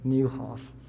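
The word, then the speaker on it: Nieuwhof: And yes, that's how you pronounce his name, Newhoff.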